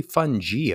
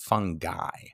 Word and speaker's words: fungi: It's actually fungi, apparently, not fungi. So fungi, and fungi, which is something that is pretty new to me to have to pronounce.